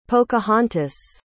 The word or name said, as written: pocahontas